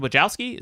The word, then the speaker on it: Wojcicki: I really liked the video you did about the YouTube CEO, Susan Wojcicki.